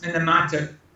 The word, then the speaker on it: Minamata: It's got Minamata, is that how it's pronounced? Can you tell us about that? – That's Minamata.